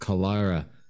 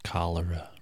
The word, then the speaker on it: cholera: A great deal of them died of cholera, and some of them are still buried by the creek. – It's pronounced cholera. – Oh, that's right. That is right.